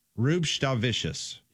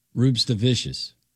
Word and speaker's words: Rubštavičius: Rubštavičius. – Emphasize the S. Emphasize the C. – Yes. – Rubštavičius.